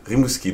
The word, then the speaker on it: Rimouski: Rimouski, did I pronounce that well? – Yeah, it's very well pronounced.